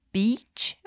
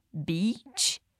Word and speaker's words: beach: Mas olha a diferença na pronúncia, porque muita gente tem dúvida disso. Beach, praia. Bitch é o xingamento. Beach, bitch. Percebeu a diferença? Então, repete comigo, sem medo de esticar bem esse i.